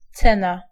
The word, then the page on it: prix: /pʁi/; Île-de-France